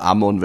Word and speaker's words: Amon: Nein, ist ja der Amon, weil er ein Ostösterreicher ist.